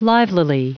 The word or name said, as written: livelily